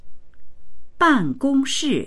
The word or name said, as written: ban4gong1shi4